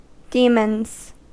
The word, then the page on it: demons: American English